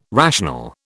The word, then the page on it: rational: British English